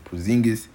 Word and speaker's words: Porzingis: Okay, I don't want to mess up his name, but I know how to pronounce his last name. Porzingis.